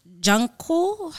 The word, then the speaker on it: Jungkook: So Lotto actually teamed up with a South Korean singer, Jungkook.